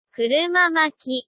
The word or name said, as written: kurumamaki